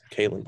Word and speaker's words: Kalen: It's Cologne Barnes out of Baylor. He ran the Kalen Barnes. – They ran the unofficial 4-2-1.